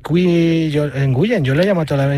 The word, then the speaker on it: Nguyen: Muchos jugadores americanos vietnamitas tienen esa, pero se pronuncia Win. Se pronuncia W-I-N, se pronuncia Win. Sí. Por ejemplo, Scotty Nguyen, que ha sido campeón del mundo, que es el Nguyen más famoso que hay, sí. Es Scotty Win, porque se pronuncia Win. Pero se escribe así, N-G-U. Y-E-N, Nguyen.